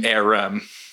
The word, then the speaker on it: Ahrem: Ahrem. – Yeah. – Ahrem. All right. So we have Alexa Ahrem from Philadelphia Zoning on our podcast today.